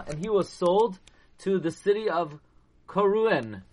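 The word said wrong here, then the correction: Kairouan: Karuen, which was one of the strongest Arabic holds in the entire Africa. Kairuan. Kairuan is the way you pronounce it.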